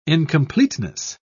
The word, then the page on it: incompleteness: British English